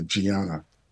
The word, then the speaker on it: Diana: Oh, it's spelled Diana, but they in Portuguese they say Gianna.